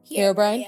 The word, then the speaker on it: Herobrine: Herobrine? – Yes.